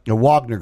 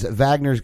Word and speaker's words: Wagner: Wagner Group. – Just with a W. – It's German, dude. It's V. Pronounced V.